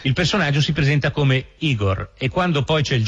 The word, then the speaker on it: Igor: Tu devi essere Igor. – No, si pronuncia Igor.